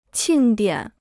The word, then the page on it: 庆典: qìng diǎn